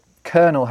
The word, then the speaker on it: colonel: When we discussed the military and military words, we talked about the fact that colonel has that silent L in it because... – Colonel, yeah. Mm-hmm. Well, actually, the way I just said it then kind of gives it away. But we are using an Italian spelling, but a French pronunciation. So the French word is coronel with an R, but the Italian was colonello.